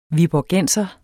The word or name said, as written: viborgenser